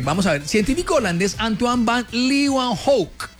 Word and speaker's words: Antoine van Leeuwenhoek: Antoine van Leeuwenhoek. Es que así se pronuncia. ¿Usted por qué se burla de mí? Estoy pronunciando en holandés, Pipe.